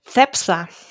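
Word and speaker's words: Cepsa: Da, wie gesagt, drauf achten, Repsol und äh, Shep der, ganz korrekt mit spanischem Spanisch ausgesprochen wird, es heißen Zepsa.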